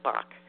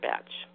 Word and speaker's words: Bach: The original flower essences were created by Dr. Edward Bach. If you live outside the U.S., you may pronounce his name as Dr. Batch.